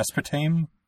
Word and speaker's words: aspartame: And it used sugar rather than corn syrup or that, how do you say that, aspartame? – Aspartame crap? – Aspartame? – Aspartame is correct.